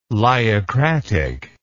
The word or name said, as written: lyocratic